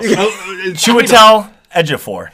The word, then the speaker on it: Chiwetel Ejiofor: Did I get it? – Nicely. Yeah, it good, looks good to me. – It was close. It was close. Chiwetel Ejiofor.